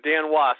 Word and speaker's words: Dan Woese: W-O-S. Woese? – Yeah, it's close. It's Dan Woese.